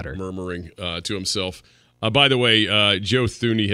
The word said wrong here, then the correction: Thuney: has been, or Tooney, not Thuney. It's spelled Thuney, but it's Tooney, has been declared out.